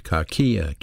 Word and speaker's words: kakia: Malice, and that's the word pronounced kakia, K-A-K-I-A, kakia.